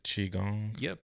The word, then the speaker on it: Qigong: Is it Qigong? – Yep.